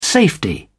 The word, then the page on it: safety: British English